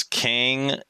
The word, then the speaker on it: Kang: Queso81 says, Kang is the actual pronunciation Korean translating to strong.